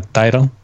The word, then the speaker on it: Tidal: Yo la voy a llamar Tidal, ¿vale? Aunque en español podría ser Tidal. Aquí en España eh, se diría Tidal, pero en realidad su pronunciación correcta, si no que me lo corrija, se llamaría Tidal. ¿No? – Eh, correcto, se llama Tidal.